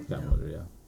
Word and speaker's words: Scott Mosier: Episode 259 of Smodcast, which Kevin Smith does with Scott Mosier, is that how you say it? – Scott Mosier, yeah.